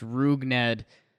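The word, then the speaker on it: Rougned Odor: Yeah, they're saying it's pronounced Rugned.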